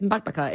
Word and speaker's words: Bakbaka: There's another recipe, and boy, I'm not confident that I will pronounce this correctly, but it's the one pan Bakbaka. That's it. You did well. That's it. Bakbaka.